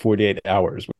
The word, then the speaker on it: 48 HRS: It's not called 48 hours. It's called 48 HRS period. And Wikipedia is very clear to set out that that is pronounced 48 hours. – That it is, in fact, 48 hours, not 48 hours. – Yes, it's not pronounced 48 Harris or something.